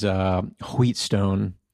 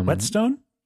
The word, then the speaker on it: whetstone: I tell you what, I got me a nice Japanese wheatstone. – Whetstone? – Nope.